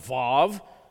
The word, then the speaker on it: Vav: And then Gimel, Daleth, Hey, Wow, or sometimes pronounced Vav, so I like Wow myself, but that's okay.